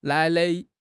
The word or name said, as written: nai3 nai5